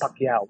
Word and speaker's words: Pacquiao: Doesn't say Pacquiao like the rest of us. Yes. He says Pacquiao. Why has he got to pronounce it like that?